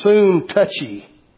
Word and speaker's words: Syntyche: And you notice I pronounced her name Syntyche, but it's been suggested, I've heard that's the way it's pronounced, that's the reason I pronounce it that way, but it's been suggested maybe the proper way to pronounce that is soon touchy.